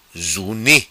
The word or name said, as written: Zuni